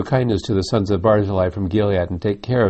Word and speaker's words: Barzillai: Barzillai, I think, is actually the correct pronunciation of that name.